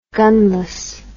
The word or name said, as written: gunless